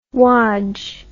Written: wadge

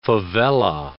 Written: favela

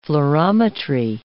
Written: fluorimetry

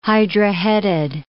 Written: hydra-headed

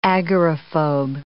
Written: agoraphobe

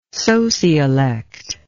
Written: sociolect

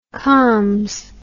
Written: comms

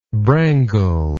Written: brangle

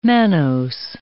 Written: mannose

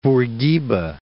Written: Bourguiba

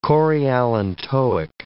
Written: chorioallantoic

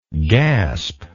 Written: gaspingly

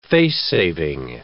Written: face-saving

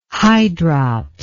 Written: hydrops

